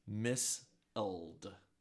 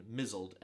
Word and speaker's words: misled: The linguist who coined the term measle named it for the mispronunciation of the word M-I-S-L-E-D as misled. Okay? As in mizzled, M-I-Z-Z-L-E-D. – Instead of misled. – Instead of misled. Have you ever been measled by the spelling of a word into thinking that it's pronounced differently? Like, say, misled is pronounced mizzled.